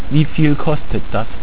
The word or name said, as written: wieviel